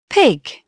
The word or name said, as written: pig